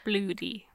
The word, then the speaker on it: bluidy: But where it's Scottish, it's spelled B-L-U-I-D-Y, Mackenzie. – Bloody.